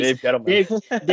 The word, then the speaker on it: Gettleman: I really did because, I mean, maybe Gabe Dettelman. Yeah, Gabe. I can't say his name. Jeez. – Dave Gettleman. – Dave Gettleman. English is hard, guys.